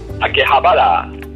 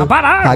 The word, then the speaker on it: Akihabara: Akihabara. – Claro, sí, sí, lo pronuncio. Akihabara.